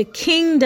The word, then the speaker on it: Qing: Dating back to about the 1600s, the Qing dynasty, which is pronounced, which is actually spelled Q-I-N-G, the Qing dynasty, which lasted 1644 to 1911, there were some laws and some codes against witchcraft.